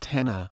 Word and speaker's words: Xa: Eliquis works by blocking factor Xa, pronounced Tena, which is a blood clotting factor critical to the blood clotting process.